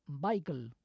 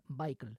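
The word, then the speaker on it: Weikl: Y ahora vamos con un cantante que es precisamente miembro honorario de la Ópera de Viena, el austriaco, el vienés, Bernd Baikl, un barítono que acaba de cumplir 80 años, ya que nació el 29 de julio de 1942. El apellido es W-E-I-K-L, y ellos pronuncian Baikl.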